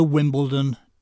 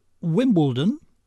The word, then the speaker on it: Wimbledon: I just wish I could understand why so many literate, well-educated Americans think that the word Wimbledon is spelt with a T. No, it's a D, same as London. I never hear anyone say Lunton. Why do so many say Wimbledon?